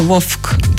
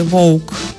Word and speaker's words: вовк: Е, люди схильні, люди, люди, які виросли в російськомовних регіонах, схильні вимовляти як вовк, так ніби там є Ф. – На німецький вольф дуже схоже. Так, але насправді українську правильно казати вовк.